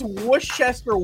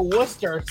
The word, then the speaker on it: Worcester: No, they pronounce Worcester, Worcester.